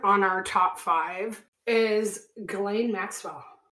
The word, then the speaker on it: Ghislaine: Ghislaine. – Oh, yeah. – Ghislaine.